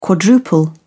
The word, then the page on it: quadruple: British English